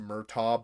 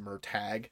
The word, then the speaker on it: Murtagh: And it looks like it's pronounced Murtaugh, but it's pronounced Murtag.